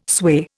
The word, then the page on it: sway: Indian